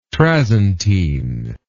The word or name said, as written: trasentine